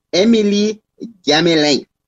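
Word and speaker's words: Émilie-Gamelin: Let me pronounce the location properly as I have it here. So it is Emily Gamelin.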